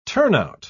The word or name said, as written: turnout